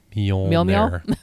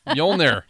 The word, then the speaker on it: Mjolnir: And his hammer, Mjolnir, how do you say that? – Mjolnir. Mjolnir.